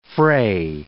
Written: Frey

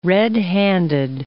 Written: red-handed

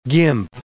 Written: gimp

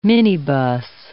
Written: minibus

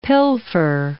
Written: pilfer

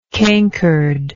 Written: cankered

